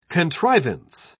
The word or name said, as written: contrivance